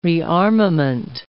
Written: rearmament

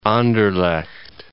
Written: Anderlecht

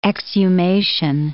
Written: exhumation

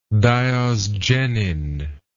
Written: diosgenin

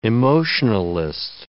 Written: emotionalist

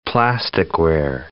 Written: plasticware

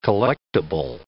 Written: collectable

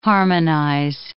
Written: harmonize

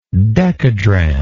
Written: dekadrachm

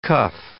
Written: cuff

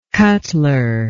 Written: cutler

